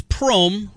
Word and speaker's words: Prohm: It is Prohm, rhymes with Rome.